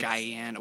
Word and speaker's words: Guyana: Oh, by the way, he's from, I believe, Guyana. If I said that wrong, it's Guyana.